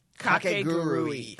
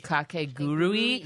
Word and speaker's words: Kakegurui: Kakegurui. – Kakegurui.